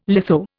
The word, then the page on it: litho: Indian